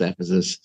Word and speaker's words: Ephesus: But they're both pronounced Ephesus. So, well, then, okay.